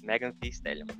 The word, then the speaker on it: Megan Thee Stallion: Não sei como se pronuncia esse nome do meio. É T? Meg... Megan T. – É Stallion. É T. T. Megan T. Stallion.